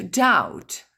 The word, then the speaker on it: doubt: To doubt. Le B ne se prononce pas.